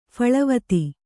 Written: ಫಳವತಿ

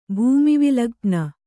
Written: ಭೂಮಿವಿಲಗ್ಪ್ನ

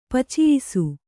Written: ಪಚಿಯಿಸು

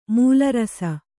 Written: ಮಲರಿಸು

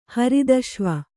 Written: ಹರಿದಶ್ವ

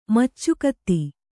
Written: ಮೇಚಕತೆ

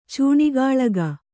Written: ಚೂಣಿಗಾಳಗ

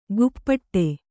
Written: ಗುಪ್ಪಟ್ಟೆ

